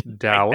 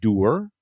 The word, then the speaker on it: dour: How do you pronounce the word D-O-U-R? Because, of course, you know, Donald Trump used that word to describe Mitch McConnell. Obviously, he didn't Dower? – That word. Yeah, you pronounce it. I pronounce it doer, but I have I have had many. You think that's wrong? I had many people who say no, it's dower. So I believe that there are alternative pronunciations, but I'm willing.